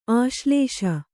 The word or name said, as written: ಆಶ್ಲೇಷೆ